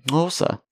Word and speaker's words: Xhosa: That's how you say it. That's the correct pronunciation. Xhosa. That's the clicky language.